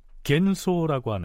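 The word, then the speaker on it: 겐소라고 (玄蘇): 자, 그건 그렇고 풍신수길이 두 번째로 보낸 사절단의 정사가 평이지라면 그 외에 부사격으로 함께 온 또 한 사람은 현소, 일본 발음으로는 겐소라고 하는 승려였습니다.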